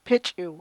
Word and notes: pich'iw: chukchansi

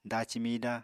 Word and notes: daci mida: apatani

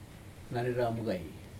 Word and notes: nareramgei: Aneityum

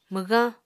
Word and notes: mwgañ: Apatani

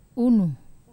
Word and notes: unu: Wamesa

